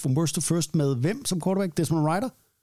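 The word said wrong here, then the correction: Ridder: Det er dernede? Ja, ja. Ritter. Ritter. Ja, der kan du bare se.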